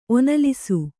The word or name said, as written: ಒನಲಿಸು